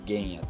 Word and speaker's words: 玄野: 玄野っていう幻によるって書いて玄野って読むんですけど